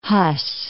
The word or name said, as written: Hus